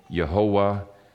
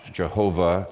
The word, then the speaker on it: Yehovah: And for our communion meditation, I want to bring the comfort of the gospel through the name Yehovah Tzidkenu, or as it's sometimes pronounced, Jehovah Tzidkenu.